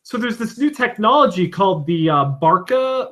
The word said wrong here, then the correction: Barco Escape: What? How do you say it? Let me say it so I could say it correctly. – Barca Lounger? – No, it's called the Barco Escape.